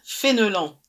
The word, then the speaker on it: Fenelon: So the way it could be pronounced is Karine Fenelon.